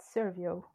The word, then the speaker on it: Servio: At the moment you're at not sure if I pronounced that correct because I think it's a French company, Adversio. Mm-hmm. – It's at Servio.